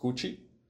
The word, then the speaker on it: CUT: In Brazil, we're focusing on CUT, which is pronounced Gucci.